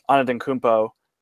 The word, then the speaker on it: Antetokounmpo: And then just the last layup I wanted to touch on here is Giannis Antetokounmpo.